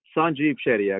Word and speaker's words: Sanjeev Shetty: It's Sanjeev Shetty, actually.